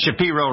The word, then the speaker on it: Shapiro: Did I pronounce Shapiro right, Ben? Yep. – Exactly right.